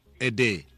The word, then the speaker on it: aider: Aider. – Aider, like in aid.